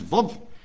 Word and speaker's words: Vav: In the Hebrew alphabet, W is pronounced Vav,